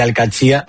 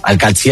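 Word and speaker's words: Al-Qadsiah: Bueno, se suele decir Al-Qadsi, pero también hay gente que dice Al-Qadsi, es que es difícil, ¿eh? Ah, no es fácil, pero Al-Qadsi es lo más fácil.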